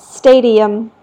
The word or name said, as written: stadium